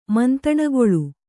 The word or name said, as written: ಮಂತಣಗೊಳು